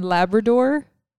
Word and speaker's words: Labrador: No, the Canadian province of Newfoundland and Labrador. Am I pronouncing that right? Do you just say it like the dog?